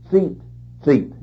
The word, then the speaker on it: tzitzit: You spell that T-A-L-I-T, talit. And the fringes on the border of that garment are called tzitzit, T-Z-I-T-Z-I-T, tzitzit.